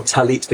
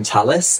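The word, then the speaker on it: Tallit: You know, my recent ancestors were Ashkenazi, and my dad did pronounce it with the S. You know, Talit became Talis, which I kind of now have rejected slightly, because I want to say the Talit, the T, is the correct pronunciation, but not to shame, of course, Ashkenazi cultural pronunciation.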